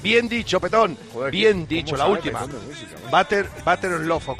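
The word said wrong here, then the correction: Better Love: ¿cómo lo he dicho? ¿Lo he dicho bien? ¿O Better? ¿Cómo se dice? Better. – Sí, tú has dicho amor de mantequilla, pero exactamente. Es Better. – Better.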